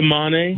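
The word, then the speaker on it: Mané: Mena, I'm sorry if I pronounce his name wrong, but is it Mena and McNamara? – It's Kakuta Mane.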